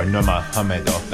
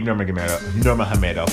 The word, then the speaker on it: Nurmagomedov: Obviously, it's been a few days since Khabib Nurmagomedov, or Nurmagomedov, that's how you actually pronounce his name, you don't pronounce the G, but I'm just a stickler for that type of stuff.